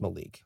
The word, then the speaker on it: Malik: It's pronounced Malik. – Whose dicks and vaginas, is it Malik? It's okay. I don't know. M-A-L-I-K, I feel like would have been Malik.